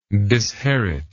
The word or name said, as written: disherit